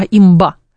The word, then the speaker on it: имба: Мне коллеги, кстати, коллеги-зумеры поправили, что не имба правильно говорит, а имба.